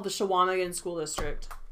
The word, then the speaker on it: Chequamegon: It's pronounced Shawamagan. Thank you very much. Yes, I'm sure. That's where I grew up, Shum. It's Shawamagan. It's not Chequamegon.